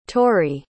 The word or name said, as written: torie